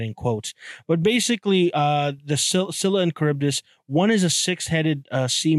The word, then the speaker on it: Scylla and Charybdis: The next one on my list today is, I actually learned how to say this properly, Scylla and Charybdis. Scylla and Charybdis.